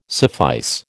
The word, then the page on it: suffice: American English